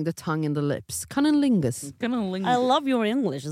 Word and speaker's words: cunnilingus: Cunnilingus. – Cunnilingus.